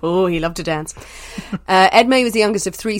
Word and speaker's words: Edme: I think I'm saying that right, Edme. It's E-D-M-E, French name Edme. As long as I don't call him Esme, then it's fine.